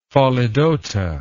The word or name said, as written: pholidota